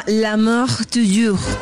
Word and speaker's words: L'amour toujours: Eso se llama L'amour toujours en français. Así tal cual se pronuncia, hay que hablar bien, ¿no?